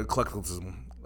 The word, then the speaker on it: eclecticism: Historically, the five interpretive approaches are preterist view, the futurist view, it's the historicist view, idealist view, or mixed view, or eclecticism. How do you say that? – Eclecticism. – Eclecticism, like the word eclectic.